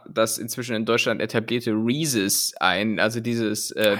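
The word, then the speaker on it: Reese's: Ich dachte Reeses, aber ja, keine Ahnung, man, ich wenn es hier in Deutschland angeboten wird, dann wird es auch deutsch ausgesprochen. – Also Reeses. – Reeses.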